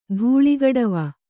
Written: ಧೂಳಿಗಡವ

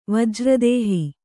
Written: ವಜ್ರದೇಹಿ

